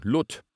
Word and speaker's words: Lot: Sein Name wird so ausgesprochen, Lot.